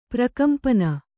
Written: ಪ್ರಕಂಪನ

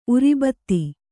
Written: ಊರಬತ್ತಿ

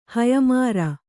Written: ಹಯಮಾರ

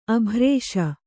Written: ಅಭ್ರೇಷ

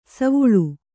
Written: ಸೋವಳಿ

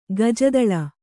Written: ಗಜದುಳಿ

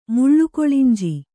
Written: ಮುಳ್ಳುಕೊಳಿಂಜಿ

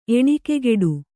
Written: ಎಣಿಕೆಗೆಡು

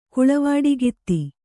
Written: ಕುಳವಾಡಿಗಿತ್ತಿ

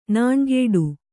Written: ನೊಣಗೇಡಿ

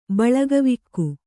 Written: ಬಳಗವಿಕ್ಕು

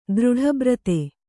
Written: ದೃಢಬ್ರತೆ

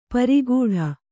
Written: ಪೆರ್ಗಟ್ಟ

